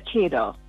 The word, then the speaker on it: cadre: I normally say Cadre or Kira, but you said Kira. Kira, and I believe that based on the Cambridge pronunciation, as I've checked, that the one you use in the newscast is actually, let's say, one of their loud pronunciations.